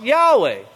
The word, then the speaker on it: Yahweh: Then lower it says, the strong probability is, get it there, the strong probability is that the name Jehovah was anciently pronounced Yahweh.